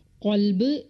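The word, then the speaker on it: ഖൽബ് (قلب): അതുപോലെ ക എന്ന അക്ഷരത്തിന് 2 ഉച്ചാരണങ്ങൾ വരുന്നു. 1 ക, 1 ഖ. കൽബ് എന്ന് പറയുമ്പോൾ നായ എന്നർത്ഥം വരുന്നു. എന്നാൽ ഖൽബ് എന്ന് വരുമ്പോൾ അതിന് ഹൃദയം എന്നാണ് അർത്ഥം വരുന്നത്.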